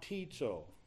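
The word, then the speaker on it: Baptizo (βαπτίζω): Baptize is a transliteration of a Greek word, baptizo. – What? – Baptizo. If I use English letters, no. Long O. Greek.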